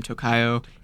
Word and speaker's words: Tocaio: I make music under the name Tocaio. It is a little weird to pronounce, but yeah, it's actually like Spanish like word.